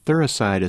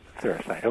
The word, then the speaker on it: Thuricide: Thuricide is spelled T-H-U-R-C-I-D-E. – T-H-U-R what? – C-I-D-E. – C-I-D-E. Thuricide, okay. – T-H-U-R-I-C-I-D-E, right? – H-O-R-I.